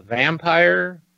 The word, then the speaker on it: Vampyr: Sword Art Online, Fatal Bullet, Telltale's The Walking Dead, The Crew Part 2, Vampire, it's spelled V-A-M-P-Y-R, game coming out.